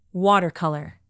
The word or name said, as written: watercolor